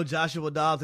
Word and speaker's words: Joshua Dobbs: I know Joshua Dobbs at the quarterback position has been a fresh breath of fresh air.